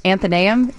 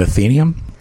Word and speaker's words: Athenaeum: The one that's spelled ATHENAEUM. – Anthoneum? Is that John Williams corrected me on Monday, so now I'm self-conscious. Anthoneum Theater? – Isn't it Athenium? – It's the Anthoneum.